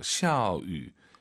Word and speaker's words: 下雨: 샤, 위, 위. 다 알아듣죠? – 可以听懂. – 그렇죠. 알아듣습니다. 하지만 입술을 동그랗게 말아서 샤, 위, 샤, 위 좀 더 좋을 것 같습니다.